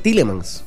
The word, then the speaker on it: Tielemans: Sí, Tielemans.